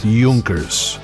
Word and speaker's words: Junkers: Junkers? The Germans, Fokkers and Junkers. What's that? I'm being told that it's pronounced Junkers after inventor Hugo Junkers.